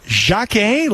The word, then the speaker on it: Jakey: Now, when we first got information about Jacquet, and it's spelled like Jakey, J-A-K-E-Y, if you're looking at his name as an English speaker, it's Jakey Josepha. Last name like Joseph with an A on the end, but it's pronounced Jacquet, like the French name Jacques, and the last name Josepha.